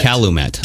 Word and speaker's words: Calumet: Calumet.